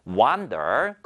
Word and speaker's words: wander: 자, 그러면 wander은 발음이 일단 좀 다릅니다. wander, 거닐다. 그 다음에 비교해보면 wonder, 궁금해하다라는 것인데요.